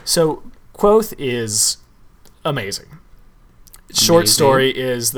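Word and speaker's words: Kvothe: and most important person that you need to know is Quoth. – Quoth? Quote? – Quoth. Quoth. With a th. Quoth. – Like the raven.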